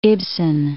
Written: Ibsen

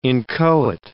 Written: inchoate

